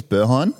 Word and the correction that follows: Berghain: Is that how you pronounce it? – We're going to go. Berheim. I think it's Berghain, actually. – Berghain. – Yeah, I'm 99% sure it's Berghain.